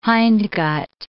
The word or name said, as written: hindgut